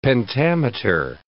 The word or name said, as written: pentameter